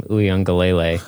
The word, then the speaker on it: Uiagalelei: And I think you are now sending DJ, however you pronounce his last name, Uiagalelei.